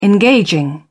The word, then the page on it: engaging: British English